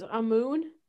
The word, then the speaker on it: Amun: It's spelled A-M-U-N, but it's the way that I heard it pronounced in a couple different documentaries that I watched clips of to try to get the pronunciation right.